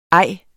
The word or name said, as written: ej